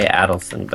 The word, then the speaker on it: Adelson: I've been told recently he goes more by Adelson. But, oh, does he? – So I've been trying to correct my previous pronunciation.